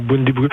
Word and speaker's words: Bundibugyo: Je pense qu'il s'appelle Bundibugyo. Je ne sais pas si je le prononce comme il faut. – Bundibugyo. – Voilà. – Ouais, Bundibugyo. Ben, la taxonomie là, des virus est un peu compliquée.